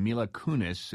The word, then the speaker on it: Mila Kunis: Well, there's a movie coming out with Natalie Portman and Mila Kunis. Is that the correct pronunciation, guys? – Right.